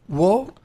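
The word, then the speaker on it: Wo (我): Wo is I, right? – Wo, yeah. – Wo? – Close enough, yes. – Wo? – Wo.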